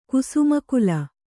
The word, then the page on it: ಕುಸುಮಕುಲ: kusuma kula